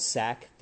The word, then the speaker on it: SAK: Over in downtown Orlando, there's a place called SAC Theater Sport. And it's spelled S-A-K.